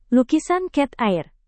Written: lukisan cat air

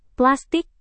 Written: plastik

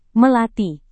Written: melati

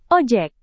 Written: ojek

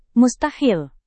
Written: mustahil

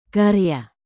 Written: karya

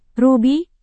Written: rubi

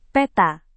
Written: peta

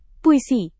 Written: puisi

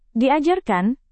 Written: diajarkan

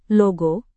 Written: logo

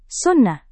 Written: sunnah

